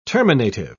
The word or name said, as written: terminative